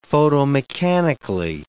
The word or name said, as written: photomechanically